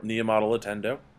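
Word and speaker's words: Niumatalolo: You're going to have to help me with the pronunciation here, but Navy's coach Niamatalo, go for it. – Niamatalotendo.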